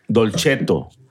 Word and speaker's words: Dolcetto: Y es Dolcetto. Dolcetto porque la C en italiano se pronuncia como CH.